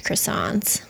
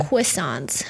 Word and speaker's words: croissant: How come you say it like that? – What did I say? – Croissants. – Croissant. – I think it doesn't have a W in it.